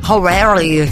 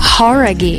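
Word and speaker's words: Jauregui: Horaghi. Who é? – Horaghi. – Horaghi. – Horaghi. Então a gente estava falando errado, cara.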